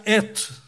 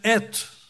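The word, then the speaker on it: et (את): Hay una palabra pequeña en el idioma hebreo que se pronuncia et y que se escribe con dos letras del alfabeto hebraico.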